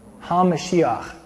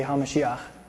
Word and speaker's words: HaMashiach: HaMashiach. Everybody say HaMashiach. What does HaMashiach mean? How do you say Jesus the Christ in Hebrew? Yeshua HaMashiach. So to say the Christ in Hebrew, the anointed one, you say HaMashiach.